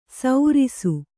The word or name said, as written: ಸೌರಿಸು